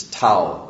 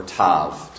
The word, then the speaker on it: Tav: It's Tau, or Tav, depends on who you talk to as far as its pronunciation is concerned.